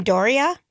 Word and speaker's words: Doria: how do you say her name? Doria? Yeah, I mm-hmm. think that's Doria accurate.